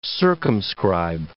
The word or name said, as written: circumscribe